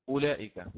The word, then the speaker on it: أولئك: وهذه الواو التي أضع عليها علامة الآن ترسم في كتاب الله تعالى ولا يتلفظ بها كما هو معلوم، يعني لا نقرأ أولياء أولئك هكذا، أو تبدأ تقول أولئك، فهي في الرسم فقط لا يتلفظ بها.